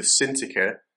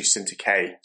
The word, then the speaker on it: Syntyche: I plead with Euodia and I plead with Syntyche to be of the same mind in the Lord. Now, That's actually Syntyche, said that wrong.